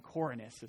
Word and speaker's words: Quirinius: This was the first registration when Quirinius was governor of Syria. I worked on that name all morning. Quirinius is how you say it.